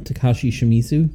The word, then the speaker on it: Takashi Shimizu: Takashi Shimizu.